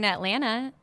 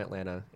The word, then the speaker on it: Atlanta: Okay, so you, yeah, my girlfriend's from Atlanta, and oh. she says you don't pronounce the last T. – Atlanta, yeah.